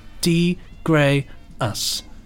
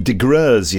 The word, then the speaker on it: De Graeus: So the first email, this one is coming up from Colin De Graeus, who has kindly put down here, pronounced De Graeus. – And I've been calling him DeGreuze, you see.